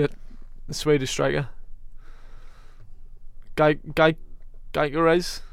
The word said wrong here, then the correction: Gyökeres: What's oh, oh, Gyökeres.